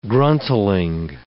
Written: gruntling

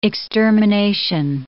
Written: extermination

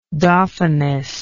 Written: dauphiness

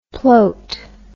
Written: ploat

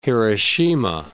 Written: Hiroshima

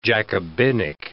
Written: Jacobinic

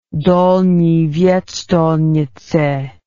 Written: Dolni Vestonice

